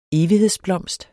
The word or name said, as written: evighedsblomst